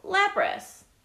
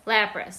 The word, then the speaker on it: Lapras: Oh, Lapras. – Okay, let's go back down. Lapras.